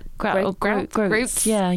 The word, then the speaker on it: groats: The grouts. So, grouts, is that how you say it? – Groats.